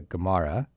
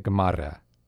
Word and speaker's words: Gamara: It is not Victoria Gamara. God help me. It is Victoria Gamara. – Oh, you didn't roll. – I didn't roll the R.